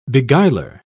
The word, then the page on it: beguiler: British English